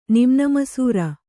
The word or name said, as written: ನಿಮ್ನಮಸೂರ